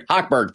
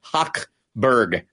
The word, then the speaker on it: Hochberg: Hochberg, thank you. Hochberg. Next time I'll phonetically write it as H-A-U-K Berg.